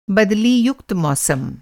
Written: बदली-युक्त

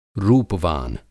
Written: रूपवान

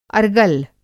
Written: अरगल

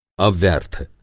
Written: अव्यर्थ

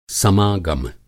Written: समागम

